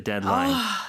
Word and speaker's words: deadline: 네, 근데 발음은 deadline. 그 D 발음이 거의 먹는 듯이 사라지죠. 그래서 deadline이라고는 하지 않습니다. 자, deadline.